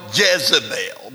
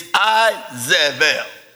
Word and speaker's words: Isabel: We call her Jezebel, but there's no J in the Hebrew language, so it was actually the correct pronunciation is Isabel.